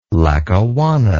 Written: Lackawanna

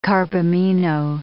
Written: carbamino

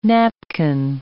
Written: napkin

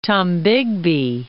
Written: Tennessee-Tombigbee Waterway

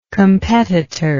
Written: competitorship

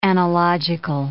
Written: analogical

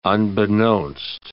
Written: unbeknownst

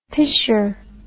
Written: pisher

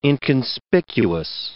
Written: inconspicuous

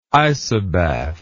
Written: isobath

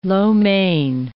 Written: lo mein

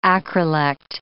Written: acrolect

